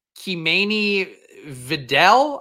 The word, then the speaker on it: Kimani Vidal: Apparently, his name is pronounced Kimani Vidal, I believe is what I'm not pulling. This isn't a bit either. That's actually how he pronounces his name.